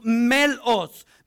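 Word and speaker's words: melos (μέλος): The members word there in the Greek is pronounced melos. Melos, which is, get this, used in antiquity as instruments of war, especially for ships.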